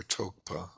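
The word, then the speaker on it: rtog pa: where Tokpa, R-T-O-G, you don't pronounce the R, is the translation of Vitarka.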